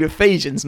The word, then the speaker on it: Ephesians: And so I went to my small group and said, is there a book in the Bible called Epaheans? And they said, yes. It's called Ephesians. You pronounced it wrong.